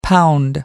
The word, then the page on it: pound: American English